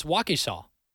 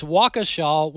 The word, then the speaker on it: Waukesha: By the way, I completely botched the name of that Wisconsin town. Marty brought it to my attention. It's Waukesha. Am I saying that right? Waukesha. It's Waukesha, Wisconsin.